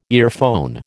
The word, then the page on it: earphone: American English